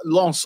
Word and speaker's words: Lens: Man said Lens. – Lens. – Oh, you're having a frigging Lance. I'll see Lance.